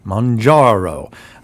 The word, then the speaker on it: Mounjaro: is called Mounjaro. You got to say it like that.